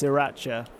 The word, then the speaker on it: sriracha: That's sriracha.